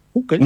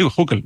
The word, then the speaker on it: Hückel: En alemán lleva una diéresis, sería una U. – Hückel. Una U pura, sí, Hückel, vale. – Pero algo así, suena más alemán, hay que pronunciarlo en placa alemán, claro, pero básicamente se parece mucho más a una U.